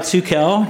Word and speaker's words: Tukel: Oh, Tukel. – Tukel. – A lot of people think it's Turkel. I think that's a popular thing that studs Turkel.